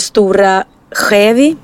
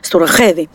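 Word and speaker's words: Stora Skedvi: Och för det första var kyrkan som ligger i Stora Skevi, heter det tror jag. Stora Skevi. Jag försöker prata dalmål här, det går sådär. Ja, men det stavas där Skedvi, fast det uttalas Stora Skevi.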